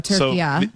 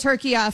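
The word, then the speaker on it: Türkiye: So there you go. Turkiya. I will be saying it Turkiya from now on.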